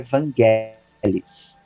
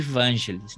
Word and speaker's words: Vangelis: A pronúncia correta do nome dele em grego é Vangelis. – Vangelis. É, e a gente, a portuguesando, chama ele de Vangelis, né? – Sonoridade bem mais bonita em português, né?